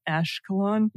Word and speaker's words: Ashkelon: Did I say that right? Ashkelon? – Yeah, nailed it.